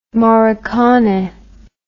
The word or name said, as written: morricone